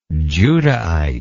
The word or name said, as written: Judahite